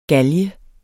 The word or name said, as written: galge